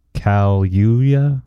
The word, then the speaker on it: Kaluuya: Kaluuya? – K-A-L.